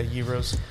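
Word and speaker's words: gyros: How do you pronounce it? Euros? Heroes? – Yeah, Euros.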